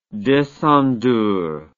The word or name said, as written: descendeur